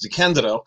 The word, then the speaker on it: DeCandido: I should point out that my last name is pronounced DeCandido.